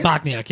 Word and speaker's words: Bochniak: Bochniak, yeah.